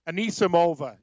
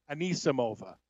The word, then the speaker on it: Anisimova: You know, the tennis name, it's actually, you want the correct pronunciation? Anisimova is her name. Anisimova.